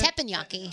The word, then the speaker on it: Teppanyaki: Teppanyaki, is that how you say it? – Teppanyaki.